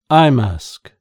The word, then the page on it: eye mask: British English